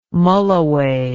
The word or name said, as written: mulloway